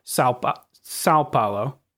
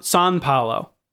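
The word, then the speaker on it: São Paulo: When I went to Sao Paulo for Cloth Map, no one, even when Brazilians were speaking to me in English, they would not say Sao Paulo. Of course, they would say, "Oops," and I'm going to butcher it here again, but it's more like Sao Paulo, or like almost sounded like San Paulo. Like right. It's close to that.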